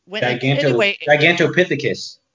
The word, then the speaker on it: Gigantopithecus: You know, you always say that you say it right, and I don't believe you. – And then I get home, and I'm like, Giganthropicus. – Gigantopithecus. – Yes, that's the guy.